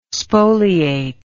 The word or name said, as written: spoliate